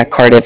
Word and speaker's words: Cardiff: Yeah, Cardiff. It's spelled C-A-R-D-I-F-F, like different, like Cardiff.